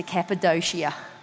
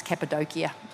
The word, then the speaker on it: Cappadocia: And then 2019, though, I'm thinking, I want to go to Cappadocia. I said Cappadocia, but it's actually pronounced Cappadocia now that I've learned that.